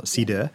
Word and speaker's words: cider: One of the things I love as well, but you worked on Stella Artois Cider, if I've got the pronunciation right. – Cider, not cider.